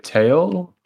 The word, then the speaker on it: Tael: His name's Tail, T-A-E-L. Good ass artist. Tail. Anyways, so. – Oh, wait, Tail? Or like. – It's pronounced Tail, like T-A-I-L, but it's spelled Tail as in T-A-E-L. – T-A-E-L.